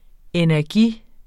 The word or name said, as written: energi